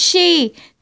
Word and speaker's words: Xi: The fever, the China's genocidal dictator, the fever, she, the fever is pronounced she, the same as Carrie Lam is a she.